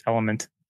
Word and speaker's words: LMNT: I think it's pronounced Element. L-M-N-T.